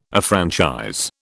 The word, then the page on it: affranchise: British English